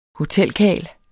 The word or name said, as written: hotelkarl